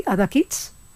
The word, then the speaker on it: ADA-KIDS: No sé si ho pronuncieu així. ¿Se pronuncia así, ADA-KIDS? – Sí. Realmente lo que ha explicado el doctor Alda es que el diagnóstico se hace por síntomas.